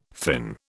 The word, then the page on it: Finn: British English